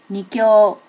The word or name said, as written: nikkyo